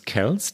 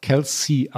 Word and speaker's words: Kelce: Travis Kels. Der Nachname schreibt sich K-E-L-C-E. Wir haben in der vorletzten Folge dann aber, ich habe einen Fehler gemacht, ihn nämlich dann doch Kelsey ausgesprochen. Dann haben wir das in der letzten Folge, in der vergangenen, korrigiert, gesagt, dass es Kels heißt und dass ich auch Kels hätte sagen wollen.